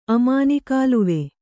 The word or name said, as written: ಅಮಾನಿಕಾಲುವೆ